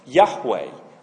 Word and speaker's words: Yahweh: The Hebrew word underneath it is pronounced something like Yahweh, Y-H-W-H.